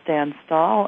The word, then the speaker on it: Stan Stahl: Our guest is Stan Stahl, and he is a cybersecurity expert with cyber information.